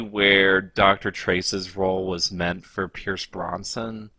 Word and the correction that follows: Brosnan: Am I mispronouncing his name again? – Yes, it's Pierce Brosnan.